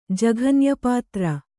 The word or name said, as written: ಜಘನ್ಯಪಾತ್ರ